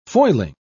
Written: foiling

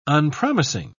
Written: unpromising

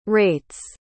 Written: rates

